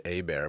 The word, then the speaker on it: Hebert: His last name is supposed to be pronounced A-Bear, but he just they put the East Texas flair on it. Anyway, we can move on. Hebert.